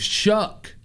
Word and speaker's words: Shough: It's like cough. And everybody in chat said it's pronounced Shuck. – I hate the Rippers and the Shippers. – Tyler Shuck, not Schauff, but his last name is spelled S-H-O-U-G-H.